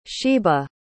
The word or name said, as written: sheba